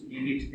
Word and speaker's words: Vivit: Das Akzent für Professor Burger dürfte nicht auf Fides gesetzt, da Glaube in diesem Sinne nur als Instrument dient. Richtig gestellt fällt die Betonung auf Vivit, da Leben das Ende und Zweck sei.